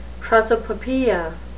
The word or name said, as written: prosopopoeia